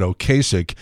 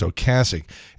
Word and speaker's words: Ocasek: And again, I stress the point that that is the correct pronunciation of his name. For years, we said Ocasek, and it turns out we were wrong all along. It's Ocasek. Anyhow, Ben Orr sang lead on many of the band's most recognizable songs, including Drive and Just What I Needed.